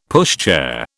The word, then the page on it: pushchair: British English